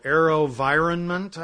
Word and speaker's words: AeroVironment: It's called AeroVironment, I think is how you pronounce it. It's environment with arrow instead of the E.